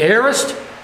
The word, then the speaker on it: aorist: the aorist, A-O-R-I-S-T.